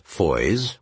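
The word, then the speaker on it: Fowey: At the little office of tourism in the center of town, they learned that the various Foys were all pronounced Foy, and that even aside from Christopher Plover, the town was something of a hotbed of fictional settings.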